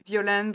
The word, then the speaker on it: Violaine: So my full name is Violaine Bria, which is spelled V-I-O-L-A-I-N-E.